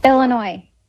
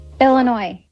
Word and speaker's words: Illinois: Illinois. – Vamos a poner la parte corta. – Illinois. – Illinois.